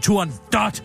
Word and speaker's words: Turandot: Allerførst så går det kongelige teater ud og siger, at det ikke udtales Turandot, men Turando. Og der var jeg altså ved at falde ned af stolen, fordi jeg fandme aldrig nogensinde i mit liv hørt Poccinis opera Turandot udtalt Turandot. Nå. På italiensk ja. udtales det Turandot, og det kommer fra det persiske ord datter Turan.